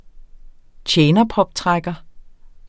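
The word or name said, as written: tjenerproptrækker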